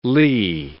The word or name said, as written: ley